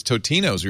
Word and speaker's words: Totino's: So we started doing our own ads for Tostino's pizza rolls. And then we found out that it was Totino's. We were saying it wrong for a long time.